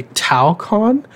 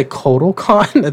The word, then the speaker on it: Talokan: I think you want to say Talokan. I feel like you're trying to say KotalCon at the same time.